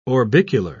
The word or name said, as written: orbicular